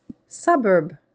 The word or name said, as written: suburb